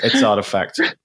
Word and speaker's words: RTFKT: Yeah, I thought you were talking about Dolce and Gabbana at first, but it's artifact.